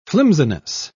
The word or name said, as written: flimsiness